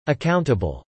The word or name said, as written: accountable